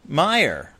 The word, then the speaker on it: Mayer: They call it Oscar Mayer or Meyer? – Meyer. – Okay, so that's, yeah, and that leads you to believe it's M-E-Y-E-R.